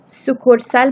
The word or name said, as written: sucursal